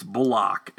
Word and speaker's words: Bullock: And he told them it's Bullock, as in lock, like you lock a door.